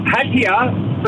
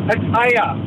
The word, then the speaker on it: Pattaya: The Aussies call it Pattaya, but it's actually pronounced Pattaya.